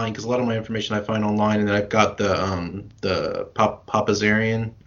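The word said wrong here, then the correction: Papazian: Yeah, Papazan.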